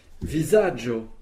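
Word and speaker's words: vizaĝo: Visaggio. – Visaggio. – Visaggio. – Visaggio. – Un visage. – Un visage, et cela s'écrit V-I-Z-A-G-chapeau-O, pour bien prononcer visaggio.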